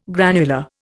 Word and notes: granular: Indian

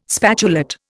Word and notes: spatulate: Indian

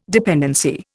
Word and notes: dependency: Indian

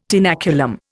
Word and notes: tenaculum: Indian

